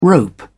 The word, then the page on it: rope: British English